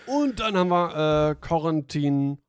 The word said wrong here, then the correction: Corentin: Oh, ne, Corentin. – Oh, glaube ich, wird es ausgesprochen.